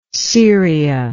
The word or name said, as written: ceria